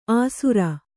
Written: ಆಸುರ

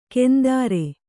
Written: ಕೆಂದಾರೆ

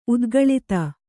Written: ಉದ್ಗಲಿತ

